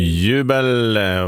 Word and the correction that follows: Jubel: var det där. Och, eller Jubel ska det uttalas, just det.